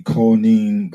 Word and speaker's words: Koine: the Koine Greek, and it's spelled K-O-I-N-E. K-O-I-N-E.